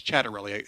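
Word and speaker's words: Ciattarelli: And Jack Cittarelli was a former, I think it's Chattarelli is how it's pronounced, actually.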